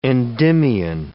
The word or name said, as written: Endymion